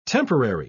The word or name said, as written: temporary